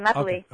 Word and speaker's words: Lapelli: Is it Lapelli? – No, you've been saying it fine, Lapelli. – Okay. – You know, it's a French last name, yeah. – So there's only, you know, you actually surprised me, you did well, because I usually get like leaply and bleh, and I get weird pronunciation.